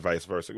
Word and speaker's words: vice versa: I appreciate you for saying that, vice versa. I want all of us to learn those two words. The name of three syllables, vice versa.